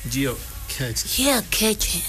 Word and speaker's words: Geocaching: La primera es Geocaching. Si no, se pronuncia así. Geocaching.